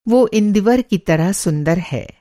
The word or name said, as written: इंदीवर